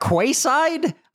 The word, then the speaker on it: quayside: Quayside is spelled Q U A Y S I D E. So, if you hear someone say quayside, that's what they're that's what they're referring to.